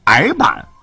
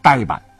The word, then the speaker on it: 呆板: 比方说，呃，说服，我们之前经常说说服某人就变成了说服；还有精米变成了梗米，叫前麻疹呢，现在变成了荨麻疹；证据确凿啊，现在就读成证据确凿；还有矮板啊，这个人笨笨的啊，很矮板，现在要读成呆板。